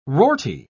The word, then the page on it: rorty: British English